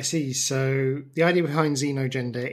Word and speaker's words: xenogender: Xenogender? – Yes.